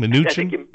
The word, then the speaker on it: Mnuchin: Mnuchin? – Steven? Use M. – Okay. – The M.